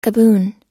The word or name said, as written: gaboon